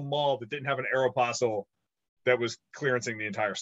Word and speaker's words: Aeropostale: They own Prince Sports, Tap Out, Volcom, Nine West, Brooks Brothers, Aeropostale, I don't know how you say it, and Izod. – Aeropostale, bro. – Aeropostale, sorry.